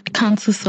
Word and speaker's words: canthus: the canthus of the eye.